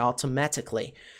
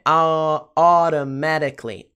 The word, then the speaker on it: automatically: É uma pronúncia que exige cuidado, tá, pessoal? Por isso que eu tô repetindo. Automatically. Você também pode falar automatically. Mas essa seria uma pronúncia mais britânica, automatically. A pronúncia americana é automatically, automatically, ó, automatically, ok?